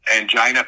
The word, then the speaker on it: angina: And the medical director on the set kept telling me, it's angina. And I said, well, I've never heard it pronounced angina. I've always heard it pronounced angina. No, it's angina, angina.